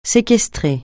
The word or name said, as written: séquestrer